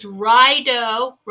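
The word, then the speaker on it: Raido: And it's, See, I always want to say radio, but it's Rido. The journey. Look at this. Don't you always feel like you're going into battle every day? Okay. It's Rido. Rido is the pronunciation.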